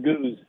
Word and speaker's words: Gooz: Raider Goose, G-O-O-Z. – Oh, Goose. All right. It was texted to me as Z-O-O-Z, but that's all good, brother.